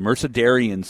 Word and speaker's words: Mercedarian: But we're happy to welcome Sister Heart of Jesus from the, and I have to make sure I say this correctly, the Mercedarian Sisters of the Blessed Sacrament.